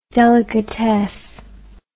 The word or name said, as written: delicatesse